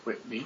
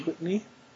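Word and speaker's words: Kwitney: Okay, it was a 7-issue thing and written by Neil Gaiman and Alyssa Quitty. I'm not sure if I'm saying that right. – I think it's Quitney. – Quitney.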